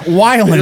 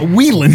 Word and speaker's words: Weiland: Scott Weiland, not Scott Whelan.